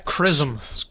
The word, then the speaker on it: XRISM: Then there's SRISM. How do we even pronounce this? XRISM. – CRISM. – CRISM. There we go.